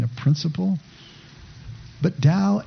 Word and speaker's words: Tao: In fact, Tao itself, which is spelled T-A-O, but pronounced Tao, means the way.